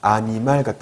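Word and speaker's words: animal: 그래서 보시면은 아니말 같은 경우는 아니말, 아, n이 있으니까 엉이라고 발음해야 되잖아요. 그렇죠? 엉. 그런데 거기에 있는 그 N이 그 뒤에 오는 모음, 즉 이에 쓰인다는 거예요. 그래서 아니말 된다는 거예요.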